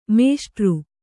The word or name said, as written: ಮೇಷ್ಟರು